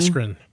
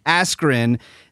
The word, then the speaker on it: Askren: Ben Askren. – Askren.